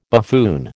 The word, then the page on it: buffoon: American English